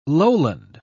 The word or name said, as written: lowland